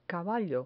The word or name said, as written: caballo